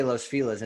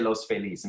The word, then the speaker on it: Los Feliz: But we all say Los Feliz.